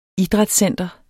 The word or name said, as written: idrætscenter